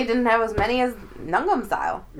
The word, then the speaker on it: Gangnam Style: Gangnam Style.